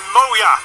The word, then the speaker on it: Moea: M-O-E-A, pronounced Moya.